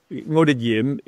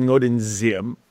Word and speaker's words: Ngo Dinh Diem: Because Ngo Dinh Diem is the way somebody in the South would call it, and Ngo Dinh Diem is somebody from the North who's more formal speaking would pronounce it.